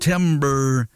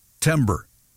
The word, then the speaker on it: Timbre: It's called Timber, but it's spelled T-I-M-B-R-E. Timber.